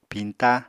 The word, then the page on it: piñta: Apatani